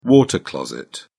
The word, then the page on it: water closet: British English